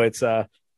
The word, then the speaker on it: pachyderm: There, boy, pachyderm. No, it's pachyderm. – Oh, so there's a loose Y that just sneaks in the middle there, huh? – I was a bit off.